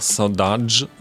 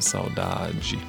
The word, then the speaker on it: Saudade: Man kann es auch Saudade aussprechen, das wäre jetzt die angolanische Aussprache davon. Aber in Brasilien heißt das eben Saudade.